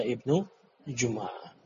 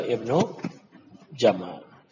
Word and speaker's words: Ibn Jama'ah: Nah, sebagian kalangan kontemporer membacanya dengan keliru, dengan membaca Ibn Jumu'ah. Tapi yang benar adalah Ibn Jama'ah.